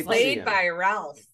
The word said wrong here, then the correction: Ralph: It's actually Rafe. I looked this up. – Rafe? – That is how you say his name. Rafe Fiennes. Or Fiennes. – Oh, Rafe Fiennes. I was like, it's Ralph. And no one in interviews calls him that. They all say Rafe.